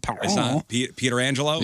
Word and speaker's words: Pietrangelo: I hope he told him how to pronounce Petrangelo and Pietrangelo? – Yeah.